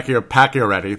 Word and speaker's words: Pacioretty: But Pacioretty, that's how they say it, strong attack in the net.